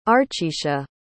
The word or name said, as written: archisha